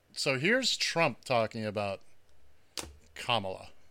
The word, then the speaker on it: Kamala: Her name is pronounced comma, like the punctuation mark, Kamala.